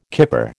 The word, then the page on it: kipper: American English